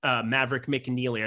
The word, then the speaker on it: Maverick McNealy: And I added my portion on Maverick McNealer. Maverick McNealy, I should be able to say his name.